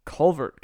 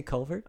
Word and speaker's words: Calvert: I'm okay with being wrong and saying Calvert County instead of Culvert County. – Do you say Culvert? – I do not. – Oh. – But that's actually how you pronounce it, I think. – Well, no, that's how they like to say it. – Yeah. – Just because, like I said, just because the locals say it that way does not mean it's right.